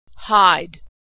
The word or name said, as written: hide